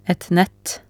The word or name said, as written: et nett